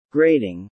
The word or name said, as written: grading